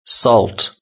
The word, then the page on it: salt: British English